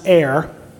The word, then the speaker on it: Er: Well, the problem is that oldest son, Er, it's pronounced heir, not a good guy.